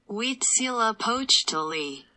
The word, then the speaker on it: Huitzilopochtli: Huitzilopochtli. – So Huitzilopochtli um, is the Aztec god.